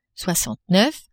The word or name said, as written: soixante neuf